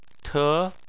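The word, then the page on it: te: tone 2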